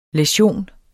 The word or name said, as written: læsion